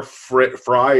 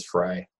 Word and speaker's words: Frey: by your mayor Fry or whatever. What's his name? How do you pronounce it? – It's spelled Frey, but yeah, it is Fry.